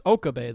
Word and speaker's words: Okabe: So Steins Gate takes place in Akihabara, and it follows the self-proclaimed mad scientist Rintaro Okabe, or as he's called himself, Hoin Kyoma. – It's Okabe, though, because, like, in Japanese, don't they always emphasize that first syllable? – Oh, you're probably right.